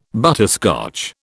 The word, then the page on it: butterscotch: British English